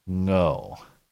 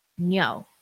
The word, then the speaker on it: Ngo: He just goes by Andy Ngo, but I remember when we had him on the show, I looked up the actual Vietnamese pronunciation and it's like Ngo. – Ngo. – Ngo. Yeah. It's kind of like the NG sound at the end of walking or talking, but with then an O. Ngo.